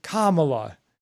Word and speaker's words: Kamala: And he was saying Kamala instead of Kamala, I think is how it's pronounced. So he was saying Kamala, and Gavin Newsom interrupted him and was like, her name is Kamala.